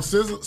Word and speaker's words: SZA: I think it's SZA. – I don't know. – Like SZA. – SZA. – Like Sizzler.